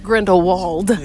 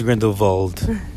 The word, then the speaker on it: Grindelwald: It looks like Grindelwald. – No, it's Grindelwald. – But it has a W. – But they pronounce it with a V.